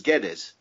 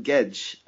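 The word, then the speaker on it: Guedes: And nobody will know what I'm talking about because from now on in, everywhere around Northern Europe is going to be pronounced as Geddes. The Portuguese, I'm assured, call him Gedge.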